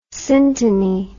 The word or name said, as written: synteny